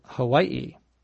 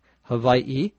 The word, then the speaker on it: Hawaii: Hawaii is spelled H-A-W-A-I-I. In the island itself, the people who live there call it Hawaii, where the W becomes like a V.